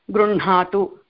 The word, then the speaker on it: गृह्णातु: Even though it was written like gruhnatu, even though it is written that way, we are supposed to pronounce it as gruhnatu.